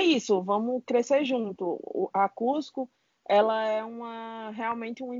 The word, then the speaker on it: Coozco: Então, o nosso Instagram é o arroba Cusco, Cusco C com dois Os, Z, C, O, se pronuncia Cusco.